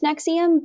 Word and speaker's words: NXIVM: And that's also, like, it's pronounced Nexium, but that's not how you spell Nexium.